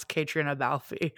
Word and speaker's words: Catriona Balfe: Can't pronounce Ireland, can pronounce Catriona Balfe.